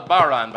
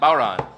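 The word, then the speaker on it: bodhrán: This is an Irish drum called a baron, by the way. – How do you spell that? – B-O-D-H-R-A-N. – Right. – Baron. – Right. – Just as you would expect.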